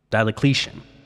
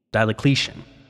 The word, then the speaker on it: Diocletian: Diocletian. – Sorry, is that with three L's instead of the two that are clearly written in his name? – Diocletian.